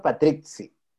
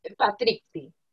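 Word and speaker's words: Patrizzi: Yo lo pronuncio Patrici, con, como con una C, pero al final son dos Z. Patrici.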